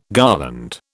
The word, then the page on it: garland: British English